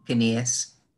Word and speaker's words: Canillas: Am I pronouncing that correctly? – Canillas. – Canillas. I'm so sorry.